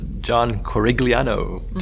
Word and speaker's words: Corigliano: So the composer, John Corigliano.